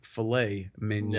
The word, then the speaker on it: filet mignon: It's filet mignon. – Now I know. Filet mignon.